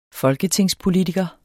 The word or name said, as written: folketingspolitiker